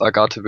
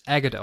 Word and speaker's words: Agate: Was ich verdammt genial fand im XD-Soundtrack, wo wir es eben angesprochen haben, ist Agathe Village und was war die andere? Phoenix City oder so? – Das wird übrigens Agate ausgesprochen. – Echt? – Das ist Oh. – das Englischwort für Ahart, ja.